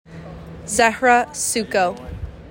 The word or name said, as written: ZEHRA SUKO